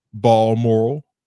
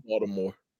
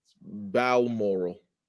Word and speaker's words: Balmoral: How'd you say that? – Balmoral. – Oh, wee. – Balmoral. – Baltimore. – How you pronounce it? – Balmoral. – Balmoral? That was from Baltimore.